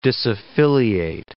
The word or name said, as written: disaffiliate